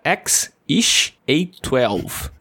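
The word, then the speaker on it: X Æ A-12: O nome em questão se soletra X, aí é um símbolo de AE juntos, não sei falar, A-12, que segundo ele é, se pronuncia X-ish A12.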